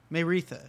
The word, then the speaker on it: Maeretha: Maritha Stewart. That name is spelled Maeretha. Maritha. Maritha.